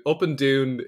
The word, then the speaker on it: Up and Doon: It's Up and Doon is the Scottish pronunciation of up and down.